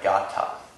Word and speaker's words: gatha: And there's something called a gata. Has anyone heard of a gata? It's spelled G-A-T-H-A, but the H is silent.